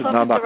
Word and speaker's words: Nabokov: It's Nabokov, right, actually. – Nabokov. – In Russian, Nabokov. – The accent is on a kof.